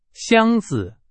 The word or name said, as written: 箱子